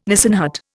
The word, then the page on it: Nissen hut: Indian